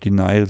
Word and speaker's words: Denial: Ich weiß nicht, ob ich es jetzt richtig ausspreche. Tease and Daniel. – Ja, ich glaube, Denial sagt man.